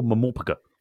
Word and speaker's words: MMORPG: And Neverwinter Nights was released as the first graphical mamopoeia. – Is that how it's pronounced? – That is how it's pronounced. – Okay. Okay. – Well, MMORPG, mamopoeia.